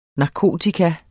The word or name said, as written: NARKOTIKAS